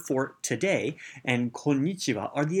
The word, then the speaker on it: konnichiwa: And in Japanese, the konnichiwa has an elongated n sound, and so you have konnichiwa is how you say it properly.